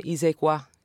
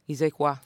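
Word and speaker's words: xe qua: Mais non, en vénitien, c'est pas sono qua, c'est ise qua. Ise qua. – Oui. – Ise qua. Ise qua. – Ça s'écrit X-E. Voilà.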